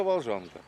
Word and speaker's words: Волжанка: Как правильно сказал это место? – Ну, это Волжанка. – Ну, это Волжанка. Да.